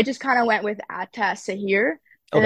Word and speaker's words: Ataşehir: You can just say Ataşehir.